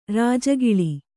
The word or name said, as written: ರಾಜಗೋಳಿ